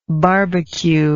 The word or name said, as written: barbq